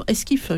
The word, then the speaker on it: Esquif: Ça vient de paraître aux éditions Esquif. Je ne sais pas si je prononce bien. – Vous prononcez très bien. C'est comme le petit bateau.